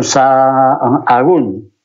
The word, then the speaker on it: Sahagún: Claro, y la F, pues que la gente estaba cansada de trabajar en el campo pues dejaron de pronunciar la F y dijeron Sahagún. Y para escribirlo, pues hay una H por el medio y ya está.